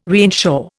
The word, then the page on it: reinsure: Indian